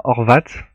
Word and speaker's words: Horvat: Donc euh, ça se prononce pas Horvat, comme j'ai pu l'indiquer euh, dans le before, c'est bien Horvat.